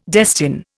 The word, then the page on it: destine: Indian